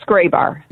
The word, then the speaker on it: Grabar: It's Graybar.